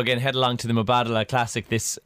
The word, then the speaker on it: Mubadala: Mubadala. – Did you? Oh no, you mispronounced it, I'd say. – Mubadala. – Oh, that seemed better.